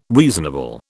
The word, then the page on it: reasonable: British English